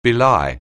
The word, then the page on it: belie: anglais